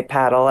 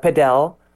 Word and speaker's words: padel: I am a bit old school and say paddle as an English speaker, and a lot of people call it padel, or it's very popular in Spanish speaking countries and they pronounce it a little bit different, like paddle.